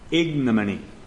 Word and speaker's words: ignominy: For in the fourth place, in verses 8 and 9, we see inescapable ignominy. Yes, that's the way you pronounce it.